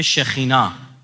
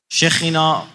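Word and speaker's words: شخینا (שכינה): در تورات با عنوان این اومده که وقتی برکت مقدس آن مرد به شخینا، شخینا معادل عبری سکینه است، اونا سین و شین تبدیل میکنن، کاف و خ تلفظ میکنن، مثلا ملخ یعنی پادشاه، ملک، ملخوت، ملکوت، اینطوری.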